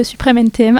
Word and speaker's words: Suprême NTM: Vous venez d'écouter à l'instant Tout n'est pas si facile de Suprême NTM. Ah, ça y est, je l'ai bien prononcé.